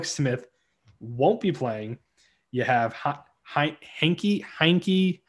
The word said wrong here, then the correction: Heinicke: Heinken, how do you say his name? Taylor Heinicke. Heinicke.